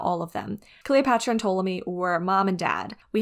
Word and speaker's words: Ptolemy: But it's spelled with a P, so the P is silent. Just FYI, if you've seen this spelled somewhere, it's Ptolemy, P silent.